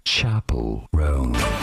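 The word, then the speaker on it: Chappell Roan: Chapel, not chapel. Chapel, Rome.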